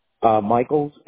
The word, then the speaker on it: Michaels: Michaels, M-I-C-H-A-E-L-S. – Got it. Gino, G-I-N-O, right? – Yes.